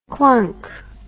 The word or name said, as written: klunk